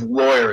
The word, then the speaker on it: Loyer: And my last name is Lawyer. It's spelled L-O-Y-E-R.